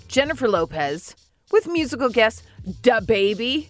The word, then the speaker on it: DaBaby: DaBaby. – That was pretty good. – I mean, I don't... She put a lot less emphasis on duh.